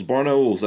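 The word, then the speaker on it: Barnaul: Barnaules, that's how you're supposed to say it.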